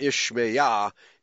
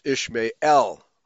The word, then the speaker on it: Ishmael: El is the third syllable, not Yahweh. It's not Ishmael, it's Ishmael.